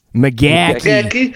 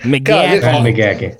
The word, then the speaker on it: McGachy: Am I saying that right? Matt McGacky. – McGacky. McGacky. – Okay, right on.